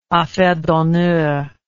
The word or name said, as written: affaire d'honneur